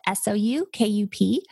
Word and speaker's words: Soukup: My last name is a little funny. It's spelled Soukup. And it's like soup cup without the middle P.